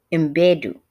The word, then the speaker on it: Mbedu: Thuso Madibu, Mbedu. Let me say that correctly. Thuso Mbedu, Lashana Lynch, this Jamie Lawson, this is it.